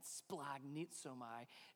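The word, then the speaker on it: Splagnitzomai (σπλαγχνίζομαι): Splagnitzomai. You remember that word? Splagnitzomai. You got to say it like that.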